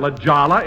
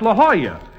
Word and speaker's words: La Jolla: Jack, that's not La Jolla, it's La Jolla. That's how you pronounce it in Spanish.